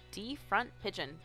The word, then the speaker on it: DFRNTPIGEON: This one is called D Front Pigeon, but it's spelled differently. So it's DFRNTPIGEON. So there's no O in the front.